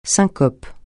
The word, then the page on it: syncope: francais